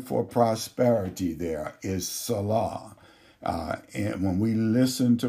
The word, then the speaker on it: Salah: The first one is called Salah, and it is spelled S-A-L-A-H, Salah, and it represents prosperity.